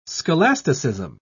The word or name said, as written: scholasticism